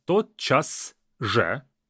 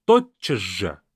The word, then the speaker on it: тотчас же: First of all, the pronunciation is very interesting. Even though it's spelled as тотчас же, we kind of read it as тотчас же. We don't say тотчас же.